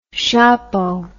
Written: shapo

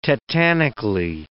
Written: tetanically

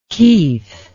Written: keith

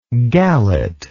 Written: galet

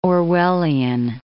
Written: Orwellian

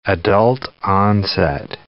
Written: adult-onset diabetes

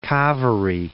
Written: Kaveri

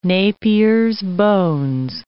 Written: Napier's bones

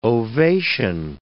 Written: ovation

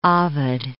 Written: Ovid

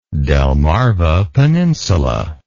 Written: delmarva peninsula

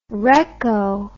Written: recco

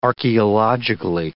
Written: archaeologically

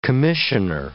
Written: commissioner

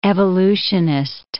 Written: evolutionist

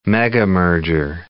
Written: megamerger

